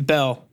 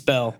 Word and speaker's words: Bell: Yeah, Bell. – No, is it Bell? – It's Bell.